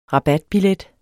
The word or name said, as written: rabatbillet